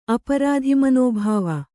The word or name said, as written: ಅಪರಾಧಿಮನೋಭಾವ